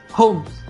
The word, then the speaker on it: Holmes: Holmes.